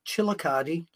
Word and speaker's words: Chillicothe: I will be in uh, Chillicothe, that we're in, rhymes with Manicotti, uh, Ohio, for uh, Bobby Fulton's World Classic uh, uh, Big Time Wrestling uh, this coming Saturday.